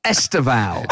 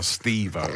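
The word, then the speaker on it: Estêvão: That's Estevao. – And if you want to play Steve-O from Jackass. – No one has ever called Estevao Steve-O.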